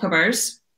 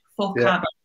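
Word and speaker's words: Fochabers: I'm going to say this slowly, it's called Fockabers. – Okay. – You'll think I'm swearing, so it's spelled F-O-C-H, Fockabers.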